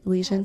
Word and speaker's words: Elysian: Did I say that right? Elysian? – Yes.